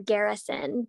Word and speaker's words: Garrison: It's Colby, and that's spelled K-O-L-B-Y, mm-hmm. and then Garrison, G-A-R-R-I-S-O-N.